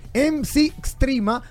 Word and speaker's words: MC Extrema: Eh, uno, uno, lo pronuncia MC Extrema, ahorita dije Mac Extrema, tomando en cuenta, ¿verdad? el, distribuidor de comida rápida que eh, eh, tiene MC y se pronuncia Mac, pero no, este es MC Extrema para todos aquellos que quieran buscarlo a través de las redes sociales.